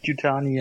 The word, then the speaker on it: Yutani: Oh, ob ich die jetzt richtig ausspreche. Wayland Yutami. Mhm. – Hm, Wayland Yutani, ja.